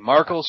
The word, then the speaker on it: Markle: Markle spelled like sparkle, but with an M.